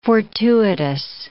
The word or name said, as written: fortuitous